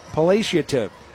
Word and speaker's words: palliative: Hospice of Holland is West Michigan's leading provider of hospice and palliative care. But I said that wrong, didn't I? Palliative. – Palliative, yes.